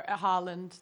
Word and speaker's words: Haaland: No, we're Haaland.